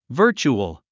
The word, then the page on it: virtual: və́ː r tʃuəl; バーチュアル